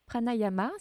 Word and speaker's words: pranayama: Ces trois outils pranayama, si je le prononce correctement.